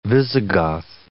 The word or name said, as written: Visigoth